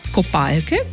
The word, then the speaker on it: kopalke: No in tako kopalke kot oblačilo pa skoraj da dosledno izgovarjamo vedno samo z L-jem, v knjižnem jeziku, no in tako se lahko zgodi, da so kopalke brez kopalk, kar je pa res lahko dvoumno, kdo česa nima.